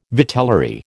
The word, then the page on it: vitellary: American English